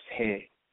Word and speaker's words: Heh: And it means, it's pronounced hey, H-E-H, but the word means sigh, to breathe, and the symbol was two arms raised to the sky, and that's ka symbol, right?